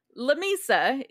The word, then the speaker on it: Lamesa: So, So Lamesa is how it's actually pronounced, but it is a combination of the Spanish words la and mesa, which means the table, because it's fucking flat out there.